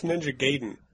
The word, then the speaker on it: Ninja Gaiden: It's pronounced Ninja Gaiden.